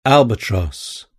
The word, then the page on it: albatross: British English